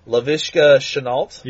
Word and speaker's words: Laviska Shenault: Laviska Shenault.